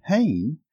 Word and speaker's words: chen: It's the Hebrew word chen. It's spelled C-H-E-N.